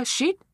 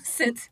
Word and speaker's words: sit: No, sit. – No. The first time, you did it perfect. – Yeah. The pronunciation is shit. Sit. Sit. – That's good. – Is just sit. Shit is bullshit.